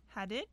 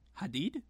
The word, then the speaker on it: Hadid: Hadid. – Hadid. – Hadid.